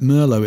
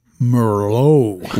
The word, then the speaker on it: Merlot: And then suddenly I realized that Americans don't pronounce it Merlot, it's Merlot.